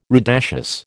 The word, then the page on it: rudaceous: American English